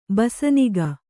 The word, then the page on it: ಬಸನಿಗ: basaniga